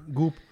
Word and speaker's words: Goop: Goop. – It rhymes with poop.